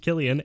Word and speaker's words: Cillian: Cillian, pronounced Killian, and Angie.